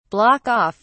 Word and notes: block off: British English

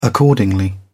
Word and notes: accordingly: British English

